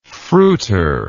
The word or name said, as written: fruiter